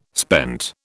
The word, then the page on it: spent: British English